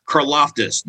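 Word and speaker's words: Karlaftis: You know, the very first mock draft, George, and I'm going to kill his last name, George Karloftis. Did I say that right? – Yes, you did.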